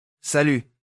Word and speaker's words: salut: Salut。 – 注意，它结尾那个字母不发音。法语经常不读最后一个辅音，它跟英语里表示致意、敬礼的那个词同源，拼写几乎一样，所以好记。和你太太的朋友熟了以后，这个词会很常用。再念一次。 – Salut。